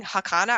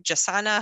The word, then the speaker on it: jacana: So I was saying Jacana. I think there's multiple ways you can. There's like Jacana, Jacana, Jacana. It's all regional, I think, but you can say it however phonetically it makes sense.